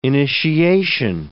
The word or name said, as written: initiation